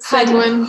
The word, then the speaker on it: sanguine: How do you pronounce it? Sanguine for everyone. It's Sanguine. So.